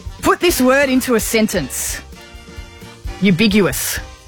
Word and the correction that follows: ubiquitous: Ubiguous or ambiguous? – Ubiquitous. – Oh, hang on, there's a D in it now.